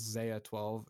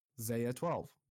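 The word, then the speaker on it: X Æ A-12: I can get expert opinions, but I'm pretty sure it's Zaya 12. It's X space AE character, the one from phonetic pronunciation, space capital A hyphen 12. Zaya 12.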